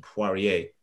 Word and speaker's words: Poirier: Poirier, hails from.